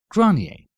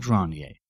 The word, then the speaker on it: Granier: Granier. Stefan Granier. That's spelled G-R-A-N-I-E-R.